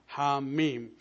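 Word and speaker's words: Ha Mim: These are actually 2 alphabets, and so we recite them separately, Ham, Mim.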